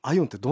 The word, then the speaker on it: IOWN: このアイオンというのは、革新的な光と無線のネットワークを意味するイノベイティブオプティカル& ワイヤレスネットワークの頭文字を取ったものなんですが、アイオンってどんな技術なんですか？ – はい、 IOWN と書いて、はい、アイオンと発音します。